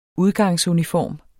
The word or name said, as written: udgangsuniform